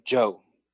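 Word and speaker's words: jiu: It is Joe, like, you know, the name Joe. In Mandarin, it's J-I-U. It's pronounced Joe.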